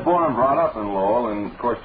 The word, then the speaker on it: Lowell: Now, take, for instance, your great city of Lowell. Think now what it would sound like if it were pronounced with the L silent. The result would be O.